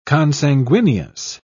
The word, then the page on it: consanguineous: British English